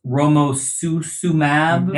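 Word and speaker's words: romosozumab: You know, and of course the generic name is always hard to pronounce. Romosusumab. – Yeah, there you go.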